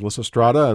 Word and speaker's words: Lysistrata: There's Lysistrata, as I pronounced correctly, yes.